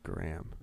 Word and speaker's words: Gram: Gram. – It's spelled G-R-A-M, not like G-R-A-H-A-M.